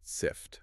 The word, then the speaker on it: Cypht: And just for the record, if you look it up, it's spelled CYPHT, Right, but it's pronounced SIFT.